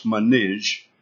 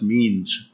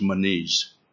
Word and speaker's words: Menes: They say it's pronounced Manij, but it's spelled M-E-N-E-S. So you probably look at it and say, oh, that's means, but it's according to the, they think it's pronounced Manij.